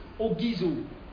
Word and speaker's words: orgizo: It's from the Greek, it's from the verb ogizo. O-R-G-I-Z-O. Ogizo. Not what you're thinking. Hallelujah. Those of you who are thinking, not this year. Ogizo. Hallelujah. So don't change the pronunciation. It's ogizo.